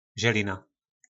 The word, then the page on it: Zelina: Po našimu